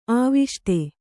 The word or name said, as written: ಆವಿಷ್ಟ